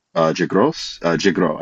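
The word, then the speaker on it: Gros: Anton Jigros, I think you don't pronounce the S.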